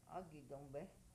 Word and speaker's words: Agidombe: Aki tombe. – Agidombe. – Agidombe? – Agidombe, that's what we'd say.